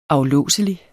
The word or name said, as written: aflåselig